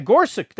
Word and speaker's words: Gorsuch: Because he said his name was Gorsuch. With a K sound at the end. Yeah, Gorsuch.